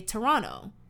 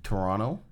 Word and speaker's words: Toronto: You guys know they always say, like, the way we say Toronto is we don't pronounce the last T. Like, Canadians, obviously, if it's at the beginning of the word, like, we'll say it, mm-hmm. but we don't like to say T's. So you don't say Toronto, we say Toronto, like N-N-O, Toronto.